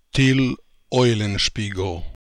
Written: Till Eulenspiegel

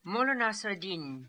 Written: Nassr Eddin Mullah

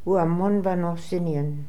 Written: Ooamonvanosinian

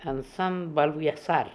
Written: Ansanbaluiazar

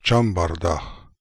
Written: chambardakh

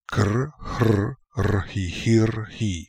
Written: Krhrrhihirhi

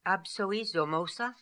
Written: Absoizomosa

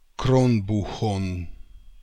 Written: Kronbookhon

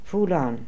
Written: Foolon

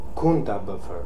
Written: Kundabuffer

